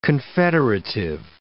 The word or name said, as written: confederative